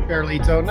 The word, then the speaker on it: Ferlito: Ferlito, no, that's good.